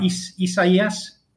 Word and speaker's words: Isaias: I've got to get the name right. Isaias? – Yeah, I think that's it. I think you nailed it.